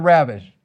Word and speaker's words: ravished: Say the word ravished. See, it doesn't mean a thing to you, does it? Because you didn't say it right. You got to roll the R. It's be thou ravished.